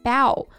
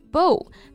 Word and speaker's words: bow: 嗯，那 bow 这个单词大家要注意发音，表示弯腰鞠躬的时候呢，发音是 bow；表示弓箭或者是蝴蝶结的时候，元音是 o bow。